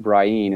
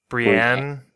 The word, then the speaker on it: Brienne: And I, by the way, I do pronounce it Brian as opposed to, what do they say in the show? – Yes. – Like, is it Brienne? – I think they say Brienne in the show.